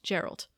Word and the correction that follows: Geralt: Geralt. – Geralt. I said it right the first time.